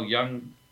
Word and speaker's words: Jung: And Carl Jung, spelled J-U-N-G, not Jung in the other pronunciation of the word.